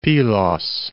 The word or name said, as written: Pilos